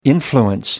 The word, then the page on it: influence: American English